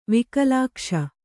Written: ವಿಕಲಾಕ್ಷ

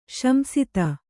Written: ಶಂಸಿತ

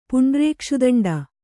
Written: ಪುಂಡ್ರೇಕ್ಷುದಂಡ

